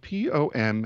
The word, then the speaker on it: pompon: Apparently, the pom-pom is spelled P-O-M-P-O-N. Pom-pon.